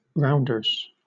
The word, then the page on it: rounders: British English